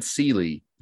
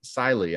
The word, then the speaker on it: Sylie: Because I've always said Sealy because it's spelled S Y L I E. And then I read in the book today, Siley, and I was like, oh, I've been mispronouncing that all this time.